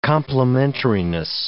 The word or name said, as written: complementariness